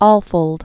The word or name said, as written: Alfold